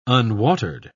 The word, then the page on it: unwatered: British English